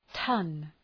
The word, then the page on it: ton: tʌn